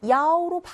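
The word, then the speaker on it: 야오로 (幺): 자, 이때 숫자 1은 야오로 바꿔서 읽는데요. 숫자 1이 2, 2, 3, 4할때 2잖아요. 근데 그게 들을 때 숫자 7, 치하고 비슷하게 들려요. 2,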